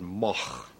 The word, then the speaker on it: Moog: M-O-O-G. – Hang on. – It's originally a Dutch name. This goes back, you know, 1,000 years or so. And in Holland, you would pronounce it Moog. Now that's a little bit too much. Yeah. To expect people to do here. In Germany, it's a fairly common name, and there it's pronounced Moog. – Ladies and gentlemen, thank you very much. Robert Moog telling you how to pronounce Moog.